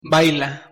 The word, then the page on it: baila: Spanish